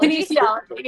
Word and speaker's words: kinesiology: Kinesiology. – Kinesiology, yeah.